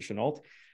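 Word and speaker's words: Chennault: Curtis LeMay and well, the aviation general Chenault, not Chenault. That always bothers me. My French language, like his name should be pronounced Chenault, but anyways, he was an American, even though he was a French American. His family pronounced it Chenault.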